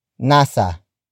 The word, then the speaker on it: nasa: The first word is nasa. Nasa. Nasa. Nasa is spelled as N-A-S-A.